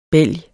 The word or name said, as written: bælg